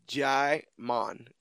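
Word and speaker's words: Djimon: Because his last name is H-O-U-N, which was Han. S-O-U. Hansu. – I always pronounce his name Demon... Dumanji? – Dumanji. It's D-J-I, so Jaimon.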